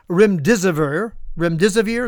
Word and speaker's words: remdesivir: It's almost certain that one of the popular treatments used by the medical establishment back then, remdesivir is how you say it, contributed to the deaths of many of these, many thousands of them.